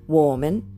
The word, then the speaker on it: 我们: ウォーメン我们。